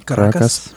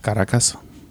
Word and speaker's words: Caracas: It was back in 2011 that the crew started brewing beer out of their garage in Caracas, Venezuela. Am I saying that right? – Caracas. – Mm-hmm. Caracas.